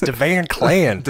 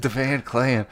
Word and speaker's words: DeVan Clan: It rhymes. It's DeVan Clan. – DeVan Clan.